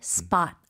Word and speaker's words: spot: 근데 발음은 스팟이 아니고 spot. – Spot. – 이름절로 spot.